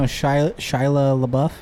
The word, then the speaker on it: Shia LaBeouf: Yeah, you guys know Shia LaBeouf? Is that how you say it? – Yep.